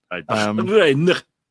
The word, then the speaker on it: Bahrain: I didn't know how to say it with the accent. – Bahrain. – That one.